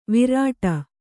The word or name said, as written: ವಿರಾಟ